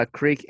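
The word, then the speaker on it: Kreuk: Yeah, Creek. I believe it's Creek.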